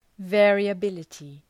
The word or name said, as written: variability